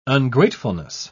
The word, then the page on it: ungratefulness: British English